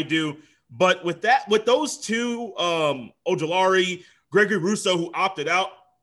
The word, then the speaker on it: Ojulari: Azeez Ojulari. I hope I said that name correctly. I'm trying to get better with names, bro, with this pronunciation and podcasting. Am I good with that? – Azeeez Ojulari. – Ojulari. Close. Wow.